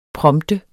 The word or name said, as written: prompte